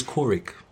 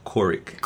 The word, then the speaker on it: Koriq: It's Kurik. Not Kurik or Kurik, that's Corick. – Okay. – As in pilot, co-pilot.